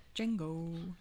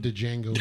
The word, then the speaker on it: Django: But the guy calls him De Django. – Yes, yeah, exactly. – And then he turns around and he says, the D is silent.